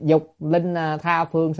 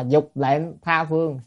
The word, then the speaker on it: linh: Mà trong khi lệnh á, âm chữ đó cùng một chữ Hán, nếu mà đọc là lệnh thì là mệnh lệnh, ra lệnh. Còn nếu đọc là linh là khiến cho. Vậy chúng ta mà đọc lệnh thì ra lệnh. Vậy dục linh tha phương sở hữu chúng sanh, mà đọc là dục lệnh tha phương sở hữu chúng sanh là à, muốn ra lệnh cho tất cả chúng sanh ở phương khác, nó đâu đúng nghĩa đâu.